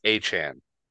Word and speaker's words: Achane: Devon Achane is actually how you pronounce his name, according to some recent articles that came out. It's like, hey, can y'all stop mispronouncing my name? It's Achane.